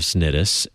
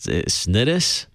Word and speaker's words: Cnidus: I don't know if I pronounced that Cnidus or Cnidus. – I think you did very well. That's exactly how I would have said it.